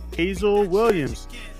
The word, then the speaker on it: Haizle Williams: Now, joining me tonight is going to be Miss Haizle Williams of Rad Magpie.